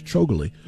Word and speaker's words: Chougule: I'm getting used to saying the name now. It's spelled with some vowels in some places. It makes me want to say it differently, but I've figured it out. It's Akash Chougule, and he did a great job.